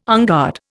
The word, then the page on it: unguard: Indian